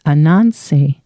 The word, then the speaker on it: Ananse: And her first name is A-N-O-K-W-A-L-E, pronounced Ana Kwale. And her last name is Anansi Simfo. So Anansi like Anansi spider. The spider, but it's all one word, A-N-A-N-S-E, another S-E-M-F-O.